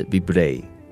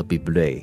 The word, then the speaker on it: Bublé: 其实我叫 Michael Bublé 叫了很多年，然后呢，最近我就查询了一下，发现读音应该是 Bublé，所以是 Michael Bublé。